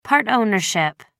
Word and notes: part ownership: American English

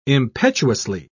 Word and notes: impetuously: British English

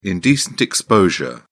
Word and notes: indecent exposure: British English

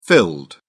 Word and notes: filled: British English

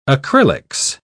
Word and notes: acrylics: British English

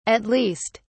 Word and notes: at least: British English

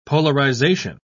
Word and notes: polarisation: British English

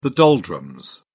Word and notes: doldrums: British English

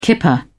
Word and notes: kipper: British English

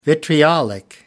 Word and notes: vitriolic: British English

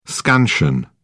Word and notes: scansion: British English

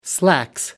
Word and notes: slacks: British English

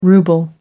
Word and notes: ruble: American English

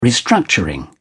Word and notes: restructuring: British English

